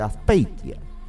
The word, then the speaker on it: Azpeitia: Muy bien pronunciado, dice Azpeitia, Azpeitia, así se dice, Azpeitia.